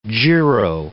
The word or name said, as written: giro